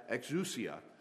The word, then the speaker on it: exousia: because he taught them as one that had exousia and not as the scribes. Exousia, I think, is how it's actually supposed to be pronounced.